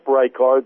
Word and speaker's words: Reichard: My name again is Chip Reichard. That's spelled R-E-I-C-H, another H-A-R-D.